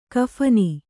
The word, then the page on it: ಕಫನಿ: kaphani